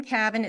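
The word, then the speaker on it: Kavin: My name is Kim Cavan. It's spelled K-A-V like Victor, I-N like Nancy. There's no C's in my name. Everybody always puts C's in my name. It's all K's.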